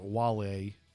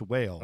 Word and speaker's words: Wale: Well, it's because you thought the rapper Wale was whale. – Pronounced whale.